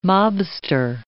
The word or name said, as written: mobster